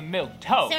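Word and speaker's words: milquetoast: I'll just be plain old boring milky toasty Diana Duquesne. – I think it's actually milk toast.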